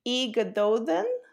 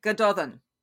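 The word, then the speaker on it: Gododdin: So I would pronounce the first text, Ygadodden. – I wouldn't say E, I would make it more like an Gododden. – Gododden, okay. – Yep, that's fine.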